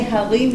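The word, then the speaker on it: harim (הרים): Horim, yes, but it's parents. They were parents. – No. Wait, it was mountains. – Hey, harim. I'm pronouncing it wrong then. – No. Hey, harim yirkedu cha'elim. We never said horim for mountains.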